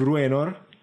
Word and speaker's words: Bruenor: Ma quindi diamo la soluzione dell'indovinello della scorsa volta che era br di tongo che non riesco a pronunciare, Bruenor. quindi Bruenor